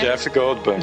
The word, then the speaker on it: Jeff Goldblum: Jeff Goldblum.